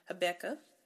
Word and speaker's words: Habakkuk: So it goes back to Habakkuk.